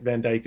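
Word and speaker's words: Van Dycke: Van Dyke is spelled different.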